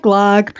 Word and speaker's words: glögg: Looks like glögg, pronounced glögg. Like I said, in Swedish and Icelandish. It's got the umlaut over the O.